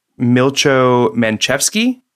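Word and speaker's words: Milcho Manchevski: So they hired a director, and Milcho Manchevsky, I think is how you pronounce his name.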